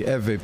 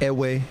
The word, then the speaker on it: Ewe: West Africa, the Ewe people. Some people mispronounce it Ewe, which if you look it up, it actually means sheep, a young sheep of a year old.